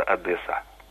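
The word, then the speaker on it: Одеса: По-украински произносится без удвоенного «с», просто «Одесса».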